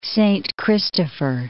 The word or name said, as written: Saint Christopher